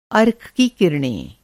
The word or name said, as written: अर्क